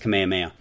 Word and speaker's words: Kamehameha: I was mispronouncing it. And my son, who has never been to Hawaii, and he's 10 years old, and he's like, Dad, it's Kamehameha.